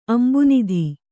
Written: ಅಂಭೋನಿಧಿ

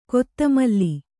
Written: ಖತಮಾಲ

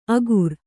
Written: ಅಗುರ್

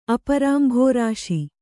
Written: ಅಪರಾಂಬುರಾಶಿ

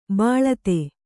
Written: ಬಿಳ್ತು

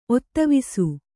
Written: ಒತ್ತವಿಸು

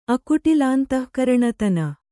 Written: ಅಕುಟಿಲಾಂತಃಕರಣತನ

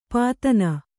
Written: ಪತನ

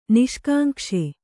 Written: ನಿಷ್ಕಾಂಕ್ಷೆ

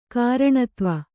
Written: ಕಾರಣತ್ವ

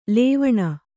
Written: ಲವಣಿ

